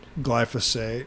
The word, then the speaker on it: glyphosate: Glyphosate. Glyphosate. I believe that's the way it's pronounced, yeah.